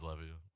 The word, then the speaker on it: Levi: I still don't know the proper pronunciation of his fucking name. – Levy. – We know I'm the best at pronunciation. – Yeah. ESPN Levy. – I think it is Levy.